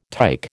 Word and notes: tike: American English

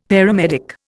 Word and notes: paramedic: Indian

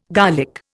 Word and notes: garlic: Indian

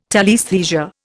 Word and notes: telaesthesia: Indian

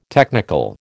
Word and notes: technical: American English

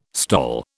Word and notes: STOL: British English

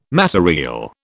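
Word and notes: matériel: British English

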